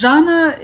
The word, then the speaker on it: Zhanna: Zhanna, is that how you say it? – Yes, Zhanna is the daughter of Boris Nemtsov, who in the early 1990s was a hugely promising politician in Russia.